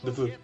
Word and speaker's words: Dafoe: ¿Cómo se le pronuncia? – ¿El apellido? Dafoe. Dafoe, vale.